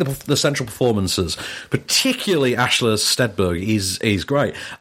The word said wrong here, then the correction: Stenberg: Amanda Stenberg.